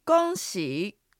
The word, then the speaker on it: 恭喜: 恭が平板の一声、喜は下がって上がる三声です。いつもの魔の発音を使ってアクセントの流れを把握するなら、まあまあという具合です。では発音と合わせて言ってみましょう。おめでとう。 – 恭喜。